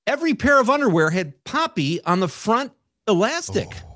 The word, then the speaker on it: Papi: P-A-P-I, which means father or dad in Spanish. – Poppy. It's not Pappy. It's Poppy. It's Poppy.